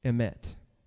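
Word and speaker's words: Emet (אמת): It's spelled Aleph Mem Tav. Emet. Emet. Emet. The truth.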